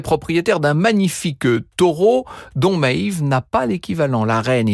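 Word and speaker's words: Medb: Alors ça s'écrit d'une drôle de façon, ça s'écrit Medbe, mais ça se prononce Maeve.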